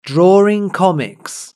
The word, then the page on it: drawing comics: British English